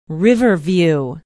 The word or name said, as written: Riverview